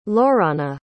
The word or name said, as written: lorana